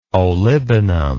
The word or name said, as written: olibanum